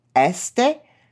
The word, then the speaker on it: Est: Because East in French is Este, and it's spelt E-S-T.